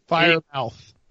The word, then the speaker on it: Freiermuth: How you say it? Is that right? – I've always said Freer, but I could be wrong. – Fire mouth.